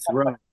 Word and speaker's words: Thoreau: Yeah, you know, one of the most memorable, well, a couple of memorable occasions, I actually learned the proper pronunciation of Henry David Thoreau. I'm supposed to throw,